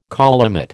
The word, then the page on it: collimate: American English